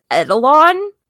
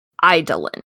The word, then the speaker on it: Eidolon: Now, I have always said this as Edelon, but you looked it up and it's actually pronounced... – Eidolon? – Eidolon.